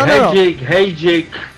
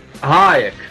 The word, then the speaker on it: Hajek: And David, I have to know, please, how is your last name pronounced? Is it Hajek or Hajek? – You can go anyway. No, Hajek. – Tell me how it's pronounced in Czechoslovakia. – Hayek. – Hayek. Oh, see, yes. Okay, that's even better. Hayek. So it's yes. Like I'm waving Hayek like that.